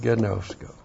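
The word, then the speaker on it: Gnosko (γινώσκω): Our old friend gnosko. G-I-N long O-S-K long O. Gnosko.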